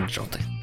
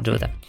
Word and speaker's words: JWT: Ну, короче, да, это, конечно, аббревиатура, поэтому вообще должно произноситься JWT, э, без вариантов. Но в коротком варианте это джоты. Никто так, конечно, не произносит, все произносят джота. В русском особенно.